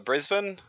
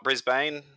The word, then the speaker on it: Brisbane: I'm from Brisbane, not Brisbane, as some people may pronounce it overseas.